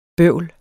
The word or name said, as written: bøvlet